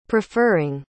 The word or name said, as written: preferring